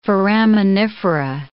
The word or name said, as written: foraminifera